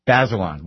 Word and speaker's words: Bazelon: Bazelon.